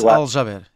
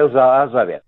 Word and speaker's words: Al-Jaber: Hamed Al-Jaber, isso mesmo. Exatamente, é Al-Jaber mas eu, nós pagamos a gasolina tão cara que eu posso lhe chamar, grosso modo, aquilo que me apetece.